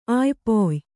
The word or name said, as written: ಆಯ್ಪೊಯ್